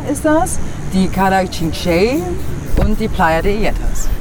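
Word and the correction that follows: Xinxell: Cinque müsste ausgesprochen werden mit X. X-I-N-X-E-L-L. Cinque.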